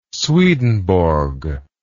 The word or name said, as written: swedenborg